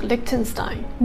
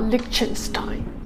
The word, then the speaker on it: Liechtenstein: It is pronounced like Liechtenstein, not Liechtenstein.